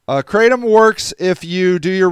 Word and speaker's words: Kratom: Okay, yeah, okay, so Emery says it comes in shots, pills, and powders, but it's called, pronounced Kratom.